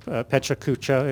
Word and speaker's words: Pecha Kucha: And some of you have maybe have heard of a presentation format called Pecha Kucha. It has various pronunciations. That's the one we've decided because it's easiest to say.